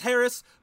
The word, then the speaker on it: Haracz: So my name is Chef Mike Haracz, and as I said, it's pronounced Haracz, but it's spelled H-A-R-A-C-Z. So it's not spelled like it sounds.